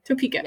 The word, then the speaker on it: Topeka: And I don't know how to pronounce one of them, so here we go. Topeka. Topeka. Topeka. Topeka. So yes. I was right. Okay, Topeka.